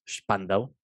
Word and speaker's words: Spandau: Pero bueno, Spandau, Spandau, como se pronuncia bien,